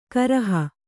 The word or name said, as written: ಕರಹ